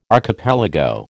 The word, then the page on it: archipelago: American English